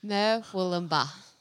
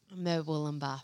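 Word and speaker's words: Murwillumbah: No. How do you say it? – Merwoolumba. – Do you have to pause like that or can you say it without the pause? – Merwoolumba. – Merwoolumba. – It's very funny. Merwoolumba. – I don't know. She always corrects me whenever I say it. So I just don't say it properly. – Mwoolumba. – Mwoolumba. So born and raised Mwoolumba. – Mm. Yep, that's it.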